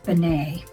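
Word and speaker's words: B'nai: So this week I need to apologize to a Reform temple in Westchester, New York, because I called them Temple Benai, and that is not correct. A listener in California, thank you, Carol, taught me that it is Temple Benai. B with that little hyphen thing, N-A-I, is pronounced Benai.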